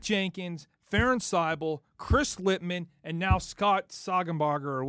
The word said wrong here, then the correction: Staudinger: or however you say his name. – Scott Staudinger.